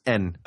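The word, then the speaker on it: N (円): It's interesting because in Japanese, yen is pronounced N, so there's no yen like this. It's not even pronounced the same.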